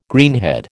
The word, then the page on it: greenhead: American English